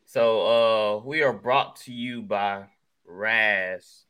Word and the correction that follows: Raze: Raze. I always say that wrong.